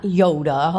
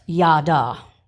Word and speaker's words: yada: No, not Yoda, although I suppose that could apply. It's the Hebrew word pronounced yada.